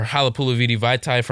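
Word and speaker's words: Halapoulivaati Vaitai: Whether it's going to be Nate Solder from the Giants, or Halapulaviti Vaitai from the Eagles, or Trent Williams. – Nice work with that pronunciation.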